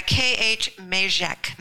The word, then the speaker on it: Khmezek: How do you say K-H-M-E-Z-E-K? – Yeah, K-H-Mejek. Mejek. That's my other name.